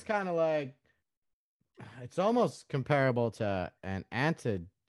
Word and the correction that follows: androgyne: Antigyne, is that right? Did I say that correctly? – Androgyne.